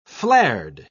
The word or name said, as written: flared